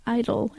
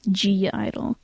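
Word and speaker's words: (G)I-DLE: Probably going to get a lot of hate because a lot of people think it's pronounced just idol and the G is silent, but they've said it themselves, G-Idol.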